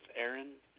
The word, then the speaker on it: Arin: It's Aaron Thompson. My first name is spelled A-R-I-N.